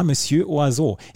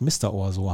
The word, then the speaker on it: Monsieur Oiseau: Es war Monsieur Oiseau. Ich musste nachgucken, hm. wie der junge Mann ausgesprochen wird. Ich habe immer gedacht, Mr. Oiseau heißt er, aber nein, Monsieur ja. Oiseau.